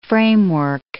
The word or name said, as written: framework